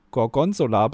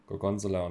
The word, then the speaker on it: Gorgonzola: Ich habe noch nie gehört, wie jemand die zweite Silbe Gorgonzola betont.